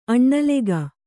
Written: ಅನಿಲಕ

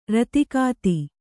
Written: ರತಿಕಾತಿ

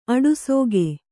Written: ಆಡಸೋಗೆ

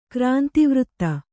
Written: ಕ್ರಾಂತಿವೃತ್ತ

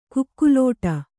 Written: ಕುಕಿಲಿಡು

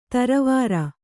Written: ತರವಾರ

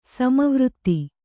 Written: ಸಮವೃತ್ತ

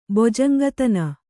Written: ಬೊಜಂಗತನ

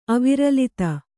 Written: ಅವಿರಲಿತ

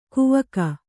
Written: ಕವಿಕೆ

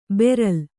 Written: ಬರ್ಲು

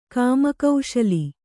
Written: ಕಾಮಕೌಶಲಿ

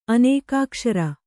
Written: ಅನೇಕಾಕ್ಷರ

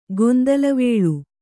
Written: ಗೊಂದಲವೇಳು

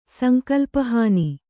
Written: ಸಂಕಲ್ಪಹಾನಿ

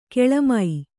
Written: ಕೆಳಮೈ

